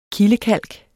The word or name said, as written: kildekalk